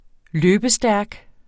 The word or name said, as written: løbestærk